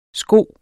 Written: sko

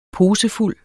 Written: posefuld

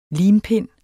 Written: limpind

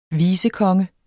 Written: vicekonge

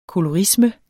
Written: kolorisme

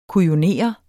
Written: kujonere

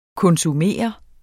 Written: konsumere

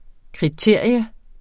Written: kriterium

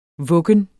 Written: VUGGENS

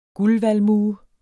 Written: guldvalmue

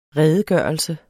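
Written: REDEGØRELSER

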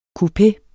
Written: coupeerne

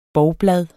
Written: bovblad

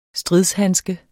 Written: stridshandske

